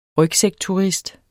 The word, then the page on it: rygsækturist: [ ˈʁœgsεg- ]